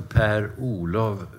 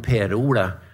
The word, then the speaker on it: Per-Olof: jeg heter altså Per-Olof, men der hemma i Joggböle, det var korrekt uttalat... Där uttalar man det lite annorlunda. Om man säger med en förslag, med Per-Ola.